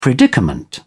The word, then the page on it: predicament: British English